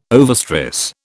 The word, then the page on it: overstress: British English